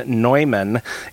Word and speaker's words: Neumann: The very next day happens to be the memorial of St. John Neumann, and we pronounce it that way because of his German heritage, N-E-U-M-A-N-N, not to be confused with John Henry Newman, the Anglican convert to Catholicism, who also lived around that same time in Great Britain.